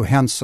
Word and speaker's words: Hens: My last name is German too, Hens, and I've had Heinz, Hens, Heinz, all sorts of things.